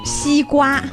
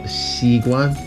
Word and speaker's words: 西瓜: 西瓜. – Oh, 西瓜.